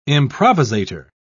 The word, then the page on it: improvisator: British English